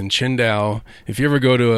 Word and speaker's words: Qingdao: it says Tsingtao, yeah, but it's pronounced Qingdao.